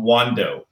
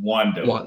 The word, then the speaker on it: Wando: I'm Wando. I'm Wando. – Wando. – Been asking, where's Wando? I love it. – It rhymes with Mondo.